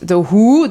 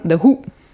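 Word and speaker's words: The Hu: Also da ist der Witz wieder, nicht The Who, die kennen wir ja alle, sondern kurz ausgesprochen The Who.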